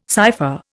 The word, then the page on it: cypher: Indian